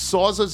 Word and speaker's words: Sauza: to 12 a.m., food and drink specials, giveaways, fun, $6 Rye Rickeys, $5 Sauza Gold Shots, Sauzas, that's how it's pronounced, $10 32-ounce Corona Familiar, $5 Tecate and Dos Equis Amber, $5 16-ounce Modelo cans.